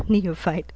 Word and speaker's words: neophyte: Neophyte, we can fight, sorry. We can type, right? Okay, there you are, neophyte. Neophyte. PH is pronounced as F, F sound.